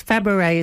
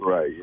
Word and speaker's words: Fabares: So also, we've said it's Fabares is the correct pronunciation, is it? – Fabares, yes.